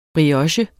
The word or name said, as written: brioche